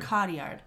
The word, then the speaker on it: Cotillard: Marion Cotillard. – Cotillard.